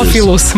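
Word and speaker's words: Theophilus: Teofilius London. Ja sam rekao, nije Teofilius. – Teofilus, malo ga skrati, ti ga produžio.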